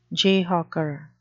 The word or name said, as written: jayhawker